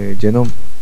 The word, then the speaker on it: GNOME: Bizim için en erişilebilir olan masaüstü genom, genom diye okunuyordu herhalde. G-N-O-M-E diye yazılıyordu yanlış hatırlamıyorsam.